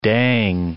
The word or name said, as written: dang